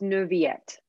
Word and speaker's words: Neuvillette: It's Neuvillette. – Neuvillette. – There's no L. It's a Y.